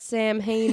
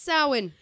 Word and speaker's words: Samhain: Not SAM-HANE indeed. But SAH-WIN.